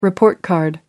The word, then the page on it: report card: British English